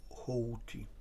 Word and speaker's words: Houthi: No więc to jest, tak się składa, że Zresztą widzę pan doktor Szewko, który wyraźnie mówi, jak sytuacja wygląda w tej chwili, że Houthi, a nie Houthi, tak jak się wymawia, tylko Houthi. A więc ci tak zwani rebelianci,